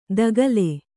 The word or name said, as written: ತಗಲೆ